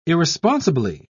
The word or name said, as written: irresponsibly